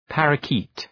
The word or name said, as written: parrakeet